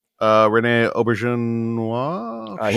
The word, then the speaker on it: Auberjonois: Rene Auberjonois.